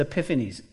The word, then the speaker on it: Epiphanes: A-N-T-I-O-C-H-U-S. A-N-T-I-O-U-C-H, Antiochus. The second one is Epiphanes, E-P-I-P-H-A-N-E-S, Epiphanes.